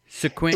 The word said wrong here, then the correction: Seguin: It's actually Seguin, Texas. – Seguin, Texas. – Yeah, it looks like Sequin, but it's Seguin.